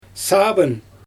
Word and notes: saabyn: Xyzyl

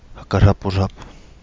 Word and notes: Hagalabulabu: Kapingamarangi

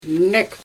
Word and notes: nek: Xyzyl

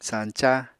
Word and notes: sañca: Apatani

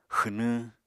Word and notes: xwnwñ: Apatani

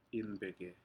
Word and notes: inpeke: Aneityum

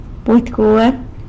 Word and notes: budy gwuar: sanlucasquiavini

